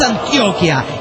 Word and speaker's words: Antioquia: Bueno, es Antioquia y se lee haciendo el acento en la O.